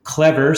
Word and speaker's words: CLEVR: Tell us more about the game that your group designed, which kind of, if I pronounce the acronym, it's Clever, C-L-E-V-R.